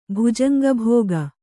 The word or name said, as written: ಭುಜಂಗಭೋಗ